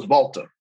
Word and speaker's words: Walter: So Walter is German. And in German and in German speaking, they don't pronounce W's as we do. Okay. So instead of like Volkswagen, it's Volkswagen. Okay. So it's Volta.